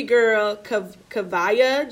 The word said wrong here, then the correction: Kaavia: James. – Kaavia. Yeah. Excuse our mispronunciations. No, it's Kav- Kaavia James Union Wade.